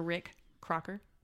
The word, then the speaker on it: Rick Crocker: Okay, we're here with Pastor Rick Crocker.